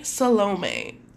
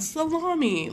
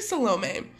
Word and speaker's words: Salome: The second woman we're going to discuss is Mary Salome. Not Salami, like I keep wanting to say. So, Mary Salome.